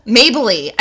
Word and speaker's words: Mabley: Mabley. I'm sorry, Mabley.